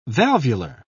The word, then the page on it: valvular: British English